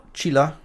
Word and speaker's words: Chilla: Jetzt frage ich dich nochmal, wie spricht man die gute Dame aus? Chilla? – Chilla, ja.